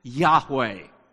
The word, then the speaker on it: Yahweh: If you were to spell that name in Hebrew, for us to put it in English letters, it's J-H-W-H, four letters, J-H-W-H. You pronounce it Yahweh, J-H-W-H.